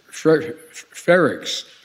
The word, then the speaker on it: Frerichs: We're going to continue to work for the release of detained Americans, including Frerichs. I want to pronounce the name correctly. I misspoke.